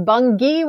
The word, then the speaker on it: Bungie: It is not. It is Bungie with a hard G.